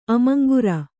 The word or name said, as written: ಅಮಂಗುರ